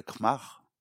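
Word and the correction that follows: Krčmář: c'est ça Je le prononce mal. – Kretschmar. – ouais. Hein.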